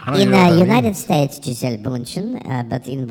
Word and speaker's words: Gisele Bündchen: In the United States, it is pronounced Gisele Bündchen.